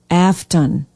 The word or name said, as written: afton